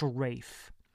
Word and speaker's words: Ralph: All we have to go on is the ramblings of a butler whose name should be pronounced Ralph, but because we're in Britain, is pronounced Rafe.